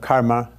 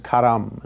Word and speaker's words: karma: Instead of karma, it's karam, and so on.